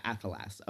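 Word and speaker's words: athelas: Yeah, he pronounces it slightly more with an E, rather than an A, so it's like, it sounds like he's saying, like, Ephelas, but yeah, Ephelas.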